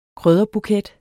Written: krydderbuket

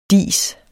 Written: dis

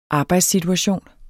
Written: arbejdssituation

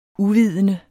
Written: uvidende

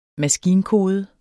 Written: maskinkode

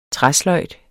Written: træsløjd

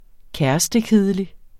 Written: kærestekedelig

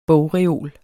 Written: bogreol

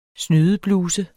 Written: snydebluse

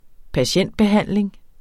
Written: patientbehandling

